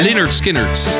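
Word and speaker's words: Lynyrd Skynyrd: Leonard Skinnert. Leonard Volgens mij spreek je het zo uit.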